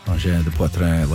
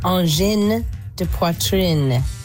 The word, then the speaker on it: engins de poitrine: And then I got a text from our friend Louise, who said the last syllable of engins de poitrine is pronounced like in because of the E at the end. Engins de poitrine, like in.